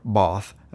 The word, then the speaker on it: Bath: Bath, or Both, as it's properly pronounced.